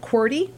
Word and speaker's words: QWERTY: That's how you pronounced it, QWERTY.